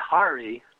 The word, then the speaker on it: Hari: Of course, when you add Hari, pronounced as the ho in hot and re as in repeat, you get an added effect.